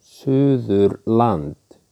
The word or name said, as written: Suðurland